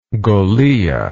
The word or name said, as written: golilla